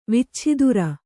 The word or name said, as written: ವಿಚ್ಛಿದುರ